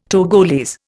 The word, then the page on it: Togolese: Indian